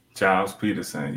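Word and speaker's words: Gilles Peterson: I think it's over a year now that I've been curating music sets, which broadcast at World FM and out of London, Gil Peterson. – Giles Peterson, yes. – Giles, thank you. I always say that wrong.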